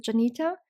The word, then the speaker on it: Dzenita: Ich heiße Janita. Das DZ, ich weiß, verwirrt sehr viele. Es wird ausgesprochen im Grunde wie ein J im Namen Jenny oder Pyjama oder wie auch immer.